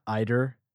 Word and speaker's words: Iter: All right, we have the jury has spoken. Iter is the pronunciation.